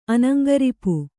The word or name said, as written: ಅನಂಗರಿಪು